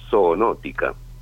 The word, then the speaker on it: zoonótica: Es una pandemia que uno la podría identificar producto de una enfermedad zoonótica. – Upa, zoonótica, de animal. – Zoonótica, exactamente, ¿no? La primera sílaba es zoo, animal, nótica.